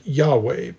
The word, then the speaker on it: Yahweh: At other points in the text, God is referred to by the Tetragrammaton, the four Hebrew letters, YHWH, and is usually pronounced something like Yahweh, but rendered as the Lord in English text, and typically in all capital letters.